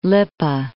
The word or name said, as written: Lippe